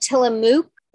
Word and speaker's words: Tillamook: It's spelled Tillamook, but it's pronounced Tillamook.